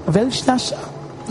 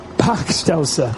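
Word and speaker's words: Bachstelze: A Weltschnäscher? Is that quite right? It's pretty rubbish pronounced here. Packstelzer. Thank you very much. Better.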